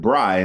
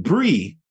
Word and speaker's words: Bri: And so, you know, my name is Worldwide Bri, and I spell it B-R-I because someone took away Worldwide Brian because I'm an avid traveler. But so I had to shorten it to Bri, but people sometimes pronounce it Bree, Worldwide Bree. – Yeah, I get that. – And I get that. Although Bree is B-R-I-E, but right.